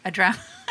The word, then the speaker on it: Andromeda: And tell us about when you started, what, Andromeda, am I saying it correctly? – Andromeda.